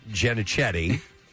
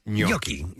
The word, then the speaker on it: gnocchi: I knew it was out of Kathy's world, but he calls it Ginocchetti. When it's gnocchi is the actual pronunciation.